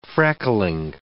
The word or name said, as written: freckling